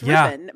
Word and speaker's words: Rippon: I love that you pronounce it like Poupon. It's Rippon. But Rippon, just Rippon.